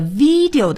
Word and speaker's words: video: 请朋友们注意这个 video 的发音啊， video 那么一定呢要用上牙齿咬住我们的下嘴唇 video。